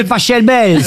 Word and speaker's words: Pachelbel: Cannelloni, c'est Pachelbel. – S'il est allemand, ça se dit Pachelbel.